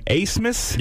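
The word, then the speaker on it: Abmas: I will tell you, Nick Hajda, my biggest takeaway, this kid's pronouncing his name Asmus. – Yeah. – Is that right? – He's got a B in it. – It's A-B-M-A-S, okay? Where I came from, that's Abmus, okay? Abmus.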